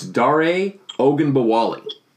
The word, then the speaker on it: Ogunbowale: It's Dare Ogunbowale.